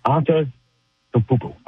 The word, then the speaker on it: Antetokounmpo: Giannis Antetokounmpo.